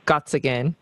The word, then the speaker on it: Gottsagen: Zack's on Instagram, and it's just his full name, Zachary Gottsagen.